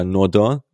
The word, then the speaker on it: Nordin: Einer, der in so eine Kerbe reinschlägt, für mich auch der qualitativ vielversprechendste Kandidat bei den Flügelspielern, das ist Arnaud Nordin oder Nordin ausgesprochen, 23-jähriger Franzose aus Saint-Étienne, ist ablösefrei im Sommer, dazu Linksfuß, was ja auch für die Gladbacher Mannschaft ganz interessant ist.